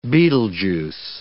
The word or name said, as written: Betelgeuse